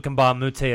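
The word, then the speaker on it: Mbah a Moute: Luke Richard Mbamute.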